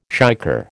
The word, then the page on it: shicker: American English